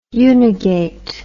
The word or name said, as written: unigate